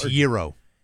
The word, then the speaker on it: gyro: Is it gyro? – It's gyro.